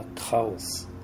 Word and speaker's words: Kraus: Eu me refiro a Karl Kraus, se fala Kraus, né? Mas aqui no português nós vamos chamá-lo de Kraus, pela pronúncia mais amistosa no nosso idioma.